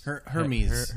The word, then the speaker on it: Hermes: What Hermes? – Hermes. Order of Hermes. – Hermes. – Actually, like, there's a clothing store. It's like Hermes. It's like French or something.